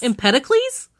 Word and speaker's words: Empedocles: Empedocles. – Empedocles? – Empedocles. E-M-P-E-D-O-C-L-E-S. D-O-C-L-E-S. – Empedocles? – No, it's Empedocles.